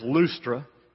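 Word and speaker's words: Lystra: The word is pronounced Lustra.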